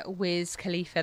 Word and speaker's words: Wiz Khalifa: Payphone there, Maroon 5 featuring Wiz Khalifa.